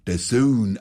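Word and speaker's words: DAZN: Das eine heißt nicht Skü, sondern Sky und das andere spricht man The Zone aus. – Mein Gott, dann braucht man halt kein Dozon.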